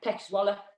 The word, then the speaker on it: Zwolle: Zwell. – Peck Zwolle.